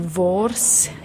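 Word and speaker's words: wors: And then vors is how you say the other one.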